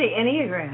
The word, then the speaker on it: Enneagram: You mentioned earlier about the, I don't know if I'm saying it right, the Enneagram. – The Enneagram. – Enneagram, yes. And say it again. – The Enneagram. – Enneagram. – It's E-N-N-E-A-G-R-A-M, Enneagram.